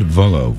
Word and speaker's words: Vullo: Music from Tom Vola. Is that right? Did I say that right? I think I did. Todd Vullo, V-U-L-L-O.